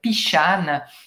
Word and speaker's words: Pixan: Et ce, rhum en particulier s'appelle le Pichan. Ça s'écrit P-I-X-A-N.